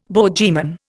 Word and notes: bogeyman: Indian

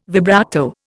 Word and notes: vibrato: Indian

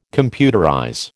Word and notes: computerize: American English

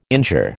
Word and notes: injure: American English

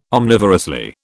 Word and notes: omnivorously: British English

